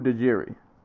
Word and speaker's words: Dajiri: Sumu Dajiru or Dajiri. Sorry, that was an I at the end, not a U. Sumu Dajiri, and they spelled his name Sumu Dajiri, Sumu Dajiri.